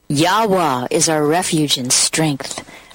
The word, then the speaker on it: Yahweh: And so we're changing the Psalms to the proper pronunciated name of the Most High, which is Yahweh, like the sound of not only the human heart, the breath, but also the sound of the ram's horn or trumpets.